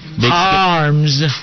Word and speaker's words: Haarms: Former Purdue center Matt Harms. Did I say his name right? – Probably not, but I know you. – How do you? H A A R M S. Harms.